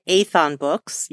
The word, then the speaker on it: Aethon: Her debut novel, Robber Barons, just came out, and it is the first installment of a trilogy from, am I pronouncing it right, Aethon Books? – Yeah. Correct.